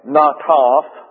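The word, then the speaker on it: Nataf (נטף): Now that's the English word, S-T-A-C-T-E. Stati is the way it's pronounced in the English. The Hebrew is N-A-T-A-F, Nataf. Those are long A's pronounced that way in the Hebrew, Nataf. And then you have State is the Greek. S-T-A-K-T-E.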